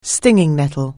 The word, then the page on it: stinging nettle: anglais